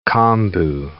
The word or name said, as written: kombu